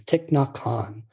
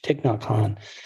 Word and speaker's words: Thich Nhat Hanh: His name is Thich Nhat Hanh. Spell that T-H-I-C-H, Thich, and N-H-A-T, and then the last name, H-A-N-H, Thich Nhat Hanh.